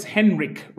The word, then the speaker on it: Henrik: It's Henrik, right? – It's Henrik. It's the German version of Henry. – All right. – Or the French is Henri.